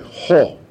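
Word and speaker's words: ho (ὁ): With the article, theos is pronounced this way, ho, which then means the.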